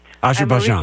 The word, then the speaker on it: Azerbaijan: And you're from, is it Azerbaijan? Is that how you say it? – That's right. – Azerbaijan.